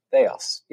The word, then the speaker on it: Theos: Theos, yeah, Theos.